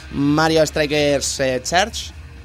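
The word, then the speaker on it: Mario Strikers Charged: A lo 3, Mario Strikers Charge.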